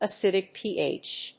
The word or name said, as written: acidic pH